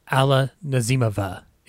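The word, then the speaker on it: Alla Nazimova: And his actress was Ala Nazimova. Ladies and gentlemen, Ala Nazimova is the way you pronounce it.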